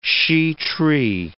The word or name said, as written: shea tree